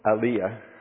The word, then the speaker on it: Aliyah: I think the Ayala, I think that's how you say it, Aliyah,